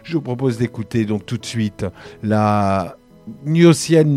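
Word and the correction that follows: Gnossienne: c'était Eric Satie, euh, donc au piano Alexandre Tarot sur les Gnossiennes numéro 4.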